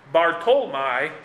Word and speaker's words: Bartholomew: Nathanael meaning God has given, Bartholomew, or as it may have been pronounced at the time, Bartolmai, son of Tolmai.